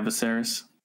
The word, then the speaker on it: Viserys: Viserys? – Yeah, Viserys.